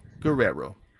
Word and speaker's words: Guerrero: Maybe, Brandon, maybe I'll stop embarrassing myself by living in San Antonio and not being able to properly pronounce Guerrero.